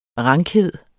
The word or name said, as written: rankhed